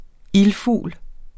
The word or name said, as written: ILDFUGLE